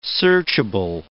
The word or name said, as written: searchable